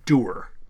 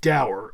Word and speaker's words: dour: And they would pronounce it doer, you know, and mm-hmm. Americans tended to pronounce it dour, and now it's going back and forth. – Huh. – So both pronunciations are in Merriam-Webster.